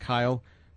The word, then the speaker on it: Cyhl: Kyle C-Y-H-L, that's pronounced Kyle, Quarles, safety from Wake Forest, spent a little time in camp with the Ravens, is now on the practice squad.